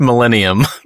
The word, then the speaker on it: Millennium: Is it millennial or millennium? – It's millennium, not millennial.